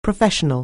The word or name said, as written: professional